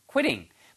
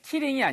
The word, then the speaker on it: quitting: quitting. 발음이 Q 발음이요. Q, Q, qui, qui 이렇게 발음하셔야 돼요. killing이 아니고 qui, qui. 입술이 쭉 나왔다가 군부원처럼 like goldfish, 그죠?